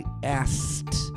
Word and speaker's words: asked: Agora um caso muito especial, que é o verbo perguntar ou pedir, o verbo ask, que no passado com ed você não fala o k. Então, por exemplo, eu pergunto, I ask, A-S-K, ask. Eu perguntei, I asked, sem o k, asked, sem o k, A-S-K-E-D, mas a pronúncia é asked, sem k.